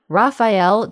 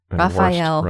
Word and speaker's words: Raphael: Next up, Raphael, don't know if you pronounce it a different way, pronounce it like the turtle.